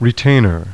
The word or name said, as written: RETAINER